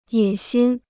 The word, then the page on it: 野心: yě xīn